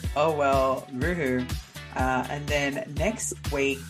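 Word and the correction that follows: hooroo: Hoo-roo. – Next week... – Not roo-hoo. – It says hoo-roo here. I just said it wrong.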